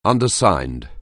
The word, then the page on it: undersigned: anglais